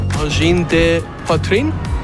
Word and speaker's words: Angine de poitrine: Angine de poitrine. – Angine de poitrine.